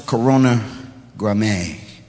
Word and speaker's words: Corona Graminea: Interesting to me, it's pronounced the Corona Graminea.